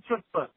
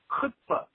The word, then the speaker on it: chutzpah: I am listening to your most recent podcast, and I want you to know that in the Jewish language, the TH is not ch, ch, it's pronounced. So you're talking what you call a chutzpah. It is chutzpah, chutzpah. How have you not heard chutzpah?